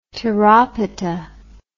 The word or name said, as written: tyropitta